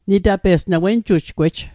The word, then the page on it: wenjujgwej: wen'jujkwej; Mi'gmaq